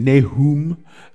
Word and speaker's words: Nahum: So Nahum, in Hebrew, his name is pronounced almost the same, Nahum. There's an O in there as well.